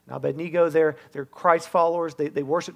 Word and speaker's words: Abednego: It's about three guys named Shadrach, Meshach, and Abednego. And that's really how you say it.